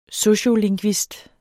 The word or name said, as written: sociolingvist